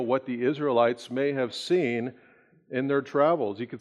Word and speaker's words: Sin: It looks like it's spilled like sin. If you had that thought, you'd be correct, because there will be sin that will happen in this desert land by the Israelites. But the Hebrew pronunciation of this word is seen.